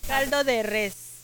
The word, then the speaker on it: caldo de res: So how do you say, como se dice, caldo de res or caldo de rey? – Caldo de res.